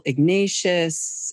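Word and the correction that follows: Ignatiev: Well, Noel Ignatieff.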